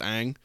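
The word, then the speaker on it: Ng: It's spelled Kim and then N-G. It's pronounced Ang.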